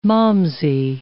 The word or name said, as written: malmsey